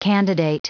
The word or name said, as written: candidate